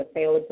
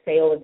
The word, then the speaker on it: Fayola: You can go to Fayola Zalika, our business coach, and my name is spelled F as in Frank, A-Y-O-L-A.